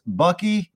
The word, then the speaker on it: Bucky: It's Bucky, rhymes with ducking, Dent.